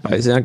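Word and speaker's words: BayCEER: Das ist dann beim Bayreuther Zentrum für Ökologie und Umweltforschung, kurz BICER, spricht man das so aus? – Oder? Ja, BICER, genau.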